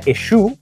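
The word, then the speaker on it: Eshoo: Ana Eshu al parecer así se pronuncia, ya dijo que está a favor de esta iniciativa.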